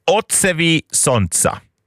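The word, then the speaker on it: Odsevi sonca: Det här är Joker Out och det ska uttalas ungefär Otsevi Sontsa.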